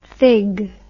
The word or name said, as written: thig